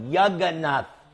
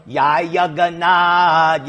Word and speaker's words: Jagannath: No, Jagannath. – Jagannath. – Some Latin people, they say Yai Jagannath, Yai, because they don't pronounce the J, but you do.